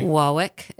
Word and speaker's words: Warwick: Warwick is how you would say that on the East Coast.